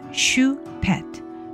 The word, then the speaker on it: Choupette: list include the name of biotech entrepreneur and Republican presidential candidate Vivek Ramaswamy, pronounced Vivek Ramaswamy, and the late Karl Lagerfeld's cat, Choupette, pronounced Choupette, who made headlines as the Met Gala honored the late Chanel designer.